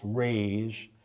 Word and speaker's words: raze: Verse 7, the word raise, sometimes spelled R-A-Z-E, it's pronounced raise.